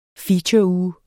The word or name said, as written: featureuge